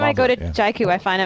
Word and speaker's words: Jaiku: And by the way, we now know how to pronounce it. It's Jaiku. We've heard it from the horse's mouths, so to speak.